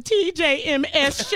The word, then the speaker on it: TJMS: I ain't going to be on here crying on no TGMS. – Don't cry. – I was saying it wrong. The TJMS show.